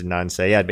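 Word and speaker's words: Adnan Syed: You mean Adna Ansayed? – Well, right, yeah, it's spelled in an odd way, right, but it's, yeah, I think we can kind of decipher it as Adnan Sayed.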